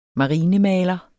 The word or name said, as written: marinemaler